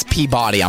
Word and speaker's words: Peabody: No, that's Peabody.